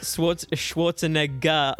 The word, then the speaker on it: Schwarzenegger: That last syllable a bit too hard. That's right. – I'm sorry. – That's not your word. – Schwarzenegger. I'm sorry. I shouldn't hit the hard R.